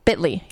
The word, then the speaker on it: Bitly: Bitly, yes.